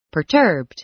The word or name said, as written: perturbed